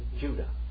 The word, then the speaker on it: Judah: We call him Judas, but what is his name? Yehuda. Judah. Judah. The King James is pronounced his name incorrectly. It should be Judah.